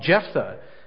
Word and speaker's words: Jephthah: But we shouldn't stop there, because then we have Jephthah. I always get that pronunciation wrong. Jephthah.